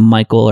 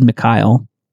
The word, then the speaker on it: Mikhail: I had broken myself of the habit of calling him Michael or Mikhail. The correct way to say it is Mikhail.